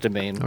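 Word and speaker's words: demesne: Do you pronounce a domain? Debesny? I didn't know that. – It's pronounced. I looked it up. It's pronounced domain.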